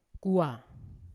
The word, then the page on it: kuwa: Yawa